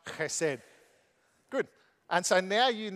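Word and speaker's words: chesed (חסד): So the way you pronounce this is chesed. So chesed.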